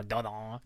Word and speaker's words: Dordogne: Early in the morning, they traveled to this river called the Dordogne, I think it's pronounced. – Sure. Dordogne.